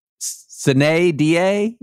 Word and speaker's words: sine die: So many of you are emailing me about Latin that my sine die pronunciation was not proper Latin. I never claimed it was proper Latin. I claimed it was Texan. Like voir dire and sine die. That is the way you pronounce it in Texas.